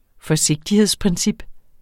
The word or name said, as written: forsigtighedsprincip